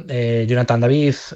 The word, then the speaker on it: Jonathan David: Kangin Lee, el delantero Jonathan David, creo que es Jonathan David, no sé si lo he dicho bien. – Sí, sí, sí.